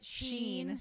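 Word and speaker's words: Shein: But like things like Shine, Is that how you say it? – Sheen. Sheen.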